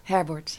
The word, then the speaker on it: Herberth: Zij zijn vandaag in gesprek met Heidroen, mm Ernst en dan daarna? – Herbert. – Herbert, ja. Ik was bang dat ik het verkeerd zou uitspreken.